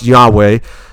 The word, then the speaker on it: Yahweh: And that name of God, as we talked, you know, it's YHVH or YHWH, but most believe pronounced Yahweh.